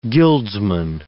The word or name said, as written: guildsman